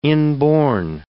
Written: inborn

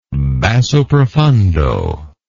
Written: basso profundo

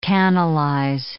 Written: canalize

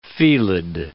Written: felid

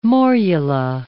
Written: morula

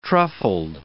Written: truffled

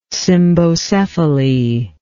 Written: cymbocephaly

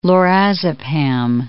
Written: lorazepam

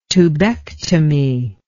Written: tubectomy

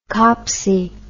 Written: copsy

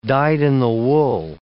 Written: dyed-in-the-wool

